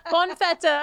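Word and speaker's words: bonne fête: bon fete. Is that how is that how you say it? Bon fete. Yeah.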